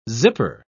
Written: zipper

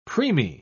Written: preemie

